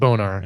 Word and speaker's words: Bonar: It is Haley Bonar. – Bonar. Are you sure it's Bonar? – I'm going to pronounce it Bonar. – All right. – Like sonar.